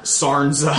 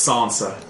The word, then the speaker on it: Sansa: So, like, I guess British people would pronounce S A R N S A Sansa. I don't like that. But Americans would pronounce it Sa- Sarnza. Sarnza.